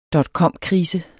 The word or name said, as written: dotcomkrise